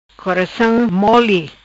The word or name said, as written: Coracam Moli